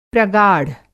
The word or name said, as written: प्रगाढ़